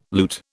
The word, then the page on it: loot: British English